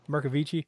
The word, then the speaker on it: Bercovici: Yeah, well, I'm kind of familiar a little bit with at least the pronunciation of Italian, and yeah, that's what I would say, Sue Bercovici. – Bercovici.